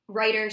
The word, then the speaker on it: Righter: Reiter spelled like right turn, like R-I-G-H-T-E-R, oh, okay.